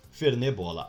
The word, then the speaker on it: Fernet Bola: Arroba Fernet, F-E-R-N-E-T, bola. Fernet Bola, mas se fala Fernebola.